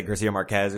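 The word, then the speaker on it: García Márquez: Yeah, I was just about to say Garcia Marquez or something.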